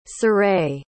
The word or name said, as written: ciré